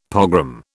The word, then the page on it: pogrom: British English